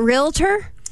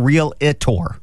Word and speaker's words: Realtor: Apparently, it's not realtor. – Oh, it's not? – It's realtor. – Oh, it's two syllables.